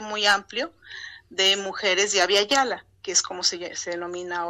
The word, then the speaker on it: Abya Yala: Abya Yala. – Abya, se escribe A-B-Y-A. – Y-A-L-A, ajá.